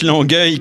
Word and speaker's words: Longueuil: Oui, mais faut le préciser parce que les deux se prononcent Longueuil quand même.